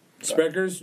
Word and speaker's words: Sprecher's: Sprecher's, Jones. Am I saying it right? – Yeah.